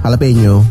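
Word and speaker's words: jalapeño: Jalapeno. – Hah? Bacanya itu ya? – Iya, jalapeno. – Kok tulisannya kan jala? – Kan kalau orang Meksiko, orang Latin ngomongnya gitu. J jadi H.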